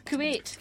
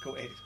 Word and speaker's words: Kuwait: Kuwait. – Kuwait, is it? – Yes, Kuwait, correct.